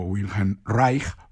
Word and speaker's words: Wilhelm Reich: que realmente cuenta la historia de Wilhelm Reich, o Wilhelm Reich, por pronunciar el alemán ahí un poco mejor, y es un psicoanalista, y su relación con su hijo Peter, desde la perspectiva, eso sí, del niño.